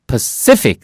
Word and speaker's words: Pacific: P-A-C-I-F-I-C. 이 단어는 부담되면 버리셔도 돼요. 잘 보세요. Pacific. C 액센트 있어요.